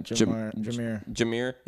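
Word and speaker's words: Jahmyr: Jameer.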